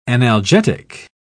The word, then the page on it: analgetic: British English